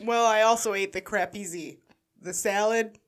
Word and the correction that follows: caprese: It has the white cheese with the green leaf and the caprese salad? – Yeah, not...